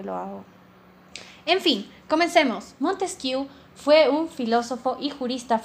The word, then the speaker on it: Montesquieu: Soy yo, su host, y hoy vamos a hablar sobre Montesquieu, dicho vulgarmente. Pronunciación correcta, Montesquieu, Montesquieu.